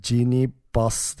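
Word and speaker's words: Jeanie Buss: E, takım sahibi e, Genie Bass diye okunuyor sanırım.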